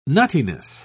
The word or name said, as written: nuttiness